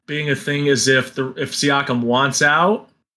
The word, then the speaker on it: Siakam: Siakam? I'm not sure if I said that right. – Siakam, yeah.